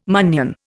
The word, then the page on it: munnion: Indian